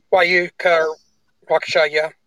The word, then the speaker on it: Waukesha: Waukesha, yeah.